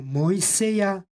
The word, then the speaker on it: Mojseja: stihu u obliku imena Mojsej, Mojseja, izgovorili smo ižicu kao j. Rekli smo, kada u srpskom ekvivalentu imamo izgovor j, tako onda i u crkvenoslovenskom ižici dajemo glas j. Stoga ovdje izgovaramo Mojseja prema ekvivalentu u srpskom jeziku. Posljednje az, slovo az, ima glasovnu vrednost ja, jer je ovdje reč o stranom imenu i az posle vokala izgovara se ja.